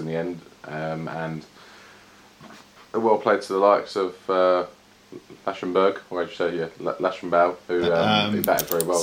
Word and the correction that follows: Labuschagne: So it's pronounced Labuschagne. Cagney, it's hard, but everyone just calls him Labuschagne.